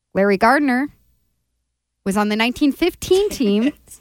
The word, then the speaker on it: Gardner: Like with a T? – No, Gardner. Sorry, just pronounced that word.